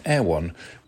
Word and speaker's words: Erewhon: And it's spelled E-R-E-W-H-O-N, Air One.